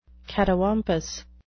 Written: catawampus

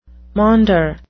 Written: maunder

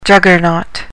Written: juggernaut

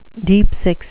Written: deep-six

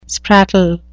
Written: sprattle

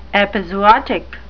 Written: epizootic

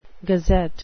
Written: gazette

